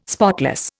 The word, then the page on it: spotless: Indian